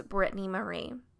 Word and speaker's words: Brittany Marie: But my name is Brittany Marie. Yes, my full name is Brittany Marie Bertelsen, but Bertelsen is Danish, right? And Brittany Marie is my first name. That's right. 4 syllables, you can handle it, I promise.